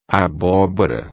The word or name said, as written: abobora